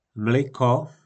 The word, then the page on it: Mlyko: po našimu